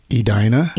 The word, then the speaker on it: Edina: So that's how you pronounce Edina? – Edina. – That's the real pronunciation, obviously, because you're from there.